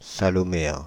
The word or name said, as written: saloméens